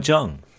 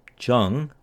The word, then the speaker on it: Chung: My name is Paul Sungo Jung, but some of you may have noticed that my name is written as Chung with a CH rather than how I've been pronouncing it, Jung with a J. And that's because in the original Korean, my family name is actually Jung.